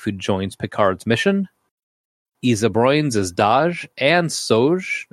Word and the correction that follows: Soji: Am I saying this right? – Soji. – Daj and Soji.